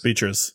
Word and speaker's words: Beatriz: Beatrix Is that how do you say it? Beatrix? – Beatrix. – Beatrix. Beatrix? Yep. Beatrix Joins the cast of Peacock's live adaptation of uh, Twisted Metal.